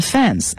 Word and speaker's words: fans: 那么 fans, F-A-N-S 它的英文的发音其实是啊这个音，就是写起来像蝴蝶那个音啊，就是 bad 那个原因 fans 啊。